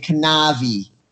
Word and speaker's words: Kanavi: Loken and Kanavi, which rhymes with Javi.